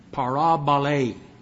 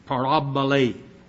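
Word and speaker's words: parabolē: Parabole. Parabole. Spelled P-A-R-A-B-O-L, and it's a long E, and pronounced like A, which is eta.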